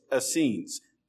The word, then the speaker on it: Essenes: But, and I had to write it out phonetically so I could say it because it doesn't look like it sounds. Essenes, and it's spelled Essenes. Essenes.